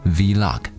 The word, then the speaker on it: vlog: 因此，想正确发音这个词，只需要取 video 一词中的 v 的发音代替 blog 中的 b 就可以了。所以，正确读音应该是 vlog。